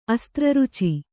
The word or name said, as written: ಅಸ್ತ್ರರುಚಿ